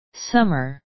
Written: summer

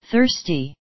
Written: thirsty

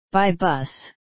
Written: by bus

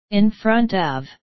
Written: in front of

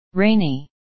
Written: rainy

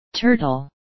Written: turtle

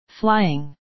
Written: flying

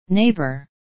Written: neighbour

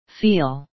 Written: feel

